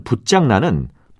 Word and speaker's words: 붓장난: 오늘 말씀드린 여러 표현 중에는 두 번째 음절의 발음이 장으로 나는 것도 있었고 짱으로 나는 것도 있었는데 말장난, 물장난, 불장난은 모두 장으로 발음하고 손장난, 붓장난은 모두 짱으로 발음한다는 것도 함께 기억해 두시기 바랍니다.